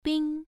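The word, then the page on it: bing: tone 1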